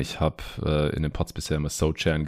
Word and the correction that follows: Sochan: gesagt, aber er hat es selber klargestellt ähm, in so einem Vorstellungsvideo, das ich neulich gesehen habe. Und dann nennen wir ihn natürlich auch so, wie er ausgesprochen wird.